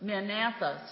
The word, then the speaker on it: Maranatha: How do you pronounce the name? – Maranatha. Maranatha. So I had the accent on the wrong syllable.